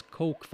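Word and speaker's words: Koch: det uttalas Coke Foundation på engelska, jag vet inte varför, men stavas Kors. K-O-C-H. K-O-C-H.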